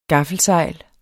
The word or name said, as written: GAFFELSEJLET